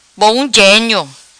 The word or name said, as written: Bom Genio